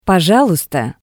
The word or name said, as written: Pogaluista